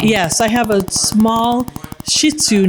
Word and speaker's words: Shih Tzu: So I listened to it, and I found this very breathy woman pronouncing it. And she said, Shih Tzu.